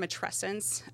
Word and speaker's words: matrescence: So, I love this term matrescence. So it rhymes with adolescence, and you think about it's this huge transition in a person's life.